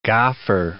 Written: gauffer

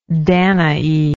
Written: danaan

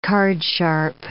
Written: cardsharp

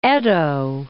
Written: Edo

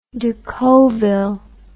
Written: decauville

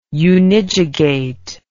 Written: unijugate

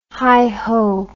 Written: hiho

